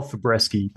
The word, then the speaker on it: Fabreschi: Karl Fabreschi.